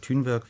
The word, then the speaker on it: Thunberg: Frau Greta Thunberg hat ja, oder Thunberg, wie sie wohl richtig ausgesprochen wird, hat ja selber dazu auch etwas gesagt.